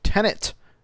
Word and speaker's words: Tenet: which is called Tenet. Like David Tennant, the actor, but you know, he's not going to star in the movie. – With T-E-N-E-T is how it's spelled.